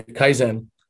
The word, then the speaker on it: Kaizen: is, and I might mispronounce it, so you can correct me, but is it pronounced Kaizen? Kaizen? – Yeah, Kaizen. Kaizen.